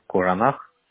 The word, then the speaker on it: Куранах: Поселок Нижний Куранах, Алданский район. – Подождите, поселок Нижний. – Ага, Куранах. – Ку или Ко пишется? – Ку. – Куронах, правильно? – Нет, Кура.